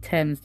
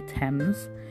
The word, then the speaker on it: Tems: Now, the three main songs that helped me, you know, gather my thoughts around this were Trouble by Thames, as Trouble by Thames, that's spelled T-E-M-S, Trouble by Thames.